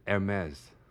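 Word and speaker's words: Hermès: 所以这牌子正确的说法就是 Hermes。哇哦。其实很简单，你不要想说它是法文，然后你觉得哦，我要怎么不发音不发音，你就想空气 air 加上 mess MS。 – 懂，但是应该这么说，我们所有人都叫他 Hermes，大概有八百年。 – 对，你知道 Hermes 是另外一个人吗？啊，不是人啊，他是他是呃希腊神话，对对对。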